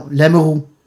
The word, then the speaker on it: Lamoureux: They pronounce it Lamoureux. – Yeah. – I was like, no, Lamoureux.